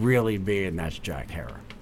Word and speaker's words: Herer: And, you know, as he always said, it's Herer and it rhymes with terror.